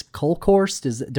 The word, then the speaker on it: Kolkhorst: We do want to get to our reader questions, but I have to mention the exchange between Hartzell and State Senator Lois Kolkhorst.